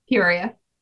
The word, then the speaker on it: Peoria: Peoria.